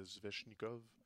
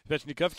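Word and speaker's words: Svechnikov: ce qui nous laisserait Zvechnikov excuse la prononciation. – non, tu l'as parfait. Zvezhnikov qui peut-être pourrait se descendre au classement parce qu'elle a Oui.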